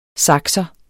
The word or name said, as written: sakser